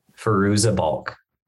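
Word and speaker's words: Fairuza Balk: Firouza Balk.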